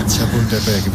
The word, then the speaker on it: Chapultepec: Chapultepec. Mejor que algún cementerio francés. – ¿Dígleses? Chapultepec, bien.